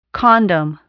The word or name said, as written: condom